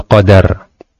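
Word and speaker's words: qadar (قَدَر): Nah, ini adalah ar-ruknu sadis al-imanu bil qadar. Al-qadaru bi fathid dal, ya, membacanya qadar itu dengan dalnya di fathah, ya. Jadi bukan qadar. Ya, bukan qadar tapi qadar. Ya, dalnya fathah.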